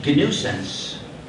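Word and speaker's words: gNewSense: But the distro GNUsense is written as G new sense.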